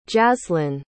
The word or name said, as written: jazlyn